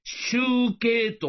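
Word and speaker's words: 醜形: 集計というのは、醜い形と書いて、集計と読むんです。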